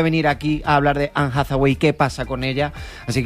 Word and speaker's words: Anne Hathaway: Anne Hathaway. – Eva, ¿está bien dicho? – Está perfectamente dicho.